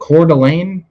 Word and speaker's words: Coeur d'Alene: What's was it Coeur d'Alene? Is that how it's pronounced in Idaho? – Yeah.